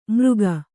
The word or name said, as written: ಮೃಗ